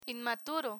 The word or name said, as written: inmaturo